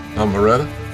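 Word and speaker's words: Almereyda: Almereda. – Right. – Almereda? That's how you say it. – It's controversial, his pronunciation.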